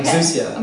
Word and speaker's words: exousia: And that authority is the word exousia. Did exousia. – I say it right? – I think you did. – Okay. – Exousia.